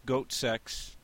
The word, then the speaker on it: Goatse: Yeah, so goat sex is GOATSE.CX. It's pronounced goat sex.